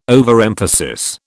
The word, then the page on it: overemphasis: British English